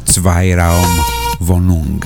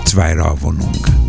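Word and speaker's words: Zweiraumwohnung: Κάποιος ανώνυμος μας έβαλε το πώς πρέπει να προφέρεται το Zweiraum Wohnung. Zweiraum Wohnung.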